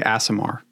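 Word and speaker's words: Aasimar: Is it Asimar or Asimar? – I say Asimar.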